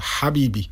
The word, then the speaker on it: habibi: Není to ha, ale tady skrko habibi.